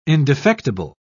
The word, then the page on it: indefectible: British English